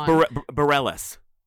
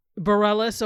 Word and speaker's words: Bareilles: I don't know if I'm saying her name right. As Dawn. – Yes, Bareilles. – Bareilles, okay. Sarah Bareilles as Dawn.